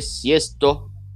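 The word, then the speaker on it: Sixto: Uh, uh, se pronuncia Siesto Rodrigues, tá? Porque ele era o, é o sexto filho de uma família de imigrantes e tal.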